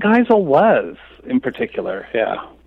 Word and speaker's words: Geisel: Geisel, yeah.